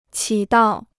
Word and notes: 起到: qǐ dào

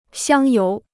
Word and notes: 香油: xiāng yóu

